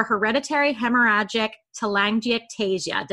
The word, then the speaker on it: hereditary hemorrhagic telangiectasia: It's called HHT, which is short for hereditary hemorrhagic telangiectasia.